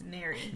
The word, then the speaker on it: Neri: N-E-R-I, I think. – Like the Mary. – Ocean. No, but... – it's nothing like Mary. It's Neri. – Neri. – Nothing like Mary at all.